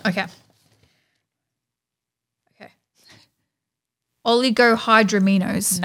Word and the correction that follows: oligohydramnios: Nope. – Oligohydraminos. – No, I don't know it. – Yeah, I just had to say it again. Oh, I feel like I didn't say it right. Dremnios. Oligohydramnios. – Okay. O-L-G-I-O. – Well, this is a sentence word. – Oh. How do I say it? – Oligohydramnios. Oligohydramnios.